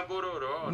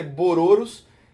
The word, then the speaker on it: Bororos: achei que era Bororó. Não, não, não. A, a pronúncia correta é Bororos.